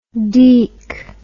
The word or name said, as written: deac